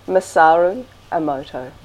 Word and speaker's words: Masaru Emoto: But we've seen the Miyamoto, is that how you say it? The work of? – Oh, you've seen Masaru Emoto, sure. – Well, yeah. How do you say his name? – Masaru Emoto.